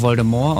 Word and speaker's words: Voldemort: Es wird ja auch Voldemort ausgesprochen, wissen viele nicht.